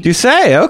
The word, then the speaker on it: Doucet: Is that how you pronounce the last name? – Doucet. – Okay. – Okay, Doucet.